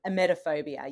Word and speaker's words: Emetophobia: Emetophobia? – Emetophobia, yeah.